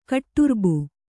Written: ಕಟ್ಟುರ್ಬು

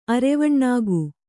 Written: ಅರೆವಣ್ಣಾಗು

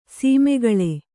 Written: ಸೀಮೆಗಳೆ

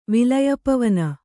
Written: ವಿಲಯಪವನ

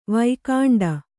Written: ವೈಕುಂಠಿ

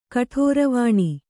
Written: ಕಠೋರವಾಣಿ

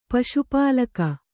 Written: ಪಶುಪಾಲಕ

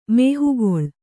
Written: ಮೋಹಗೊಳ್ಳು

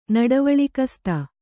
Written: ನಡವಳಿಕಸ್ತ

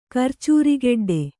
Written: ಕರ್ಚೂರಿಗೆಡ್ಡೆ

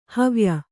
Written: ಹವ್ಯ

